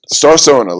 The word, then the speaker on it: Starsona: Starsona, like persona, but Starsona.